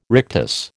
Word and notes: rictus: American English

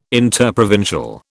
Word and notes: interprovincial: British English

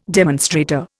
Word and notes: demonstrator: Indian

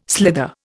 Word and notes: slither: Indian

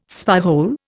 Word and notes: spyhole: Indian

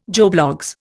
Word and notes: Joe Bloggs: Indian

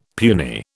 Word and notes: puny: British English

